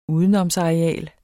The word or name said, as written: udenomsareal